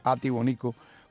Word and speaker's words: Jatibonico: Hoy vamos a conversar con alguien de la ribera del río Sasa, desde Jatibonico, el antiguo Jatibonico, cuando la H se pronunciaba como J, o sea que es Jatibonico con H, como era.